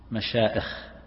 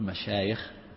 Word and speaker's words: مشايخ: لكن يقولون هنا معائش غلط في اللغة، لأن الياء أصلية، فمن ثم فإن قراءتها بالهمز غير صحيحة، ولهذا من الخطأ أن تقول مشائخ، مشائخ هذا غلط، بعض الناس هكذا يكتبها أو ينطقها مشائخ.